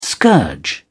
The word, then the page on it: scourge: British English